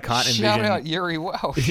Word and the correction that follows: Jiri Welsch: Uh, is that a silent J, is it? Sorry, sorry, I, I, uh, Jiri Welsch.